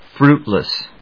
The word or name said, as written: fruitless